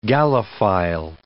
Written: Gallophile

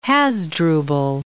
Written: Hasdrubal

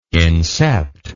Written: incept

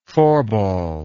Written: phorbol

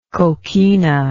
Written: coquina

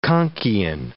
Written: conquian

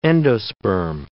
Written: endosperm

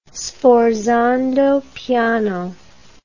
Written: sforzando piano

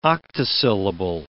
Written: octosyllable

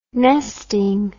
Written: nesting

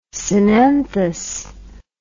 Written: synanthous

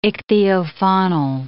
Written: ichthyofaunal

